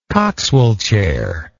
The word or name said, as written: coxwell chair